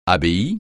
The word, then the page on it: abbaye: francais